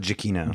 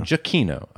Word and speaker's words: Giacchino: How do you say his name? Giacchino? – Michael Giacchino. – Giacchino. Okay. I believe that he kind of is, he's been on his way to becoming the new John Williams, it feels like.